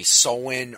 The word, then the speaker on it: Samhain: I know it looks like it's pronounced Sam Hain, and I know that there is a punk band called that they pronounce it Sam Hain, but the way I've heard the word pronounced is usually Sowin or Sawin.